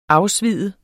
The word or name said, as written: afsvide